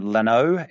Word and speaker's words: Lanoux: I think you'd pronounce her last name Lanoux, L-A-N-O-U-X.